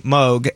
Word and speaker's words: Moog: I appreciate it when people say my name correctly, Moog, and not like Matt.